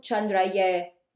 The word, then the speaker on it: Chandraye: The YA or YE, it's pronounced ye, is more formal. Chandraye.